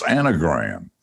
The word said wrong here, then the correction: Enneagram: Enneagram. – Enneagram? – Yeah. Yeah. Like the word any at the beginning. Like not just one gram, it's enneagram.